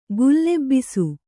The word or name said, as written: ಗುಲ್ಲೆಬ್ಬಿಸು